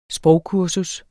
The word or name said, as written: sprogkursus